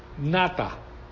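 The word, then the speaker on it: nata (נטע): Entonces, y ahí pues la palabra hebrea es nata, así como suena en el español con el acento en la A, nata, que significa plantar, establecer, fijar, extender.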